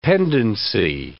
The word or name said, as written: pendency